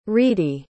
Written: ridhi

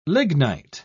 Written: lignite

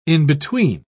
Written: in-between

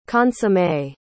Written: consommé

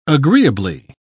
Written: agreeably

